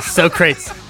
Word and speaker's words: Socrates: Zach, it's pronounced Socrates. – So, oh, yeah, Socrates.